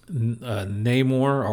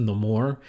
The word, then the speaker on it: Namor: Of course, we get introduced to Namor, or as it's pronounced, Namor, which I love that about the character.